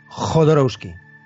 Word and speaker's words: Jodorowsky: Yo sé que no es fácil de pronunciar, pero así es, Jodorowsky.